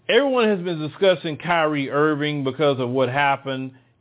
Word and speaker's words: Kyrie: Okay, well, we were speaking about Kyrie.